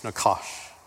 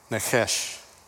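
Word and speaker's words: Nakash (נחש): It's like N-C-H-S-H, Nakash. That's how you say it. This is the Hebrew word for serpent. Nechesh. It's the same word, just a different pronunciation.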